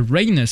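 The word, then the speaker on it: Reyne: Et donc, ça va être, il s'appelle Rain, ça s'écrit R-E-Y-N-E, e, si vous voulez euh, réécouter euh, sur euh, YouTube.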